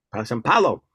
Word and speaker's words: São Paulo: São Paulo.